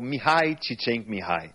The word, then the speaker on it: Mihaly Csikszentmihalyi: É o Mihaly Csikszentmihalyi, que faleceu, infelizmente, recentemente, em 2021.